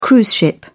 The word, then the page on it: cruise ship: British English